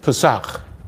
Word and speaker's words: Pesach: P-E-S-A-C-H. Shavuot. Pesach. – Pesach. Yeah, that's Passover. – Oh, okay. So okay. I said it right? – Yeah, Pesach.